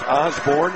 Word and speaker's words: Orsborne: His last name is spelled O-R-S-B-O-R-N-E, pronounced Osborne.